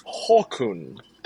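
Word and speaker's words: Håkon: Also, I'll correct my pronunciation there. It is Hakon the Eighth.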